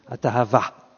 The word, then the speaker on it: Hatahava: There's a famous expression, kibroth atahama. – Atahava. – Atahava.